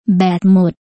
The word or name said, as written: แบตหมด